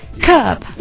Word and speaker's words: kerb: Oh, 我可以把它发音成 curb 吗？curb, 或者是 curb. – That's right, K-E-R-B.